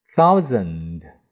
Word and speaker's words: thousand: 还有千 thousand 千 thou 我们一咬 thou 你看这里就是 th 啊咬舌尖的 ou o u 发 ou。这个 and 呢？这个 a 要发，可以发弱音 a 啊，也可以不发音。